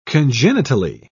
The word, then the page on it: congenitally: British English